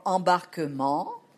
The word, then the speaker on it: embarquement: We don't pronounce E-N-T. We don't pronounce E-N-T in French. That's the ending. It's a conjugation. But when it comes to an adverb like gentiment, E-N-T, we pronounce it. So remember another rule. Endings or conjugation, present, third person plural, we don't pronounce ENT, but we pronounce it for an adverb, gentiment, or embarquement. Nouns and adverbs, we pronounce ENT in the end, but not the verbs, not the third person plural.